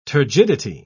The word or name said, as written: turgidity